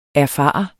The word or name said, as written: erfare